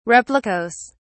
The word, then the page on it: replicas: British English